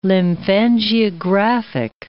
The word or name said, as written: lymphangiographic